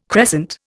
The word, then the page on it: crescent: Indian